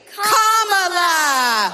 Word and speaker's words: Kamala: First you say comma like a comma in a sentence. – Then you say la like la la la la la. – Put it together and it's 1, 2, 3, Kamala.